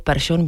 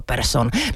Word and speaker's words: Persson: Tak upoutal hlavně tím, že začal používat výslovnost příjmení Larsson místo českého způsobu Larsson nebo Persson místo Persson. No tak ono od té doby, co se o tom začalo hovořit, to má jako velký průběh a změny.